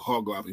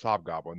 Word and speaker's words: Hobgoblin: Hobgoblin.